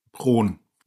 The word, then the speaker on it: Rhone: Aber wie spricht man es jetzt korrekt aus? – Rhone. – Rhone. – Einfach Rhone. – Ganz einfach Rhone. Ich habe mit mir selbst ein bisschen gerungen, ist es Rion oder Rhone oder Rhön oder ähnliches.